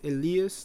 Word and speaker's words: Elias: My name is Elias, that's how you pronounce it. I know for the Americans only speaking people, it's kind of hard to pronounce it. You can call me Elias if it's easier, or Eli.